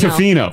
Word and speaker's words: Tofino: Then we went to Tofino, and they're like, stop hitting toe hard. It's Tofino. – That's you in a nutshell. Someone pointed out Pearl Jam. – Yeah, that's true. – Pearl Jam. – Tofino.